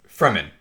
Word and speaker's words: Fremen: I mean, the Freemen are obviously Arabs. I mean, yep. Like, very clearly, that's what they are. – You pronounce it Freeman? – Fremen. – Fremen, okay. – Fremen, sorry. Yeah, Fremen.